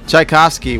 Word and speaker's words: Tchaikovsky: Tchaikovsky? – Tchaikovsky, what an honor to be in your orchestra.